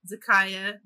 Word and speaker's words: Zakiya: It's The Other Black Girl by, how do you say her name? Zika Dahlia Harris? – I want to say Zakiya. – There we go. Yeah. Zakiya Dahlia Harris.